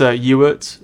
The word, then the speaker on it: Ewart: No, it's Ewart. Sounds like Stuart, but take off the ST at the beginning.